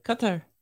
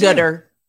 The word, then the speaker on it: Qatar: Not gutter. – Yeah. – I think the Americans made fun of Qatar and they turned it into gutter. Because it's not supposed to be pronounced gutter.